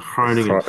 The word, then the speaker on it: Groningen: He played his best football at Groningen. – Can you say that again? Groningen, is that Groningen? – You gotta like get the phlegm going on the G, like Groningen.